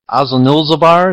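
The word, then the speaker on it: Azanulbizar: What this reminds me of is the end of the Battle of Azul Nulzabar. Is that the correct pronunciation? – Yes, yes.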